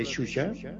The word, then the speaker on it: Zhuzha: Eh, la leyenda nos habla de Shusha. Eh, se escribe Z-H-U-Z-H-A. – Ah, Shusha.